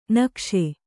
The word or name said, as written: ನಿಕಸ